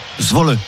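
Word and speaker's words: Zwolle: Comment tu prononces le club néerlandais? Zwol. – Zwol. – Zwol. Ah, Zwol, on prononce le très bien.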